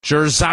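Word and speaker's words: Jer'Zhan: How do you say his first name? – Jerzon. – Because they call him Johnny Newton. – Jerzon.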